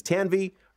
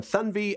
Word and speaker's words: Tanvi: And says her first name doesn't sound like the way it's spelled, so introducing herself as Tanvi rather than Thunvi, as it's supposed to be pronounced, meant she didn't have to constantly teach or negotiate with people about her name.